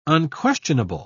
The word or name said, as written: unquestionable